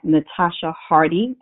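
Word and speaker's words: Natascha Hardee: I'm on LinkedIn at Natasha Hardy, and that's spelled N-A-T-A-S-C-H-A. Last name is H-A-R-D-E-E, Natasha Hardy on LinkedIn.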